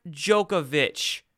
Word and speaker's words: Djokovic: That's how you say his name. You don't pronounce the D there. It's Novak Djokovic.